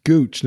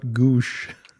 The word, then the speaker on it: Gooch: It's Gooch, not Goosh. Like La Bouche.